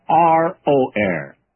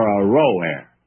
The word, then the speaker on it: Aroer: Now, I probably ought to go back and give you another pronunciation of that. The cities of Aroer, Aroer, are forsaken. And let's settle for Aroer.